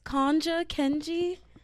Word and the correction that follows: Ketanji: Kanja Jackson, the first black woman to become a U.S. Supreme Court literally, let me see, let me. Con. Conja Jackson. I'm making sure. Ketanji, Ketanji Jackson. I wanted to make sure I had her name right because I'm like, there's no way I'm about to butcher this girl's name.